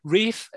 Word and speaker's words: Ralph: Yes, but it's spelled Ralph, but he says Rafe. – Let's give him his kudos. Rafe is an outstanding actor.